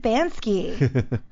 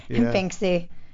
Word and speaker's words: Banksy: But Bansky's, yeah, look up Bansky, B-A-N-K-S-Y, Banksy. – Oh, Banksy? – I always read it. I've been saying it wrong. – Yeah. Banksy then? – Banksy. Oh, my God. I've always said Bansky.